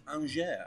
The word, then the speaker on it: Angers: In other news as well on Friday, Marseille beats Angers, apparently is how you're meant to pronounce it.